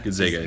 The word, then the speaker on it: Gonzaga: The funny thing about the pronouncing, is it Gonzaga? – Gonzaga.